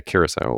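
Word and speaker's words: Curaçao: Siesta Key silver rum, Malibu coconut rum, Bowles Blue Curacao? Is that how they say it? – Liqueur. Right? – Mm-hmm. Yeah, Curacao liqueur. That's right, from Curacao.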